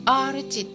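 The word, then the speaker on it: R値: で R 値って読むん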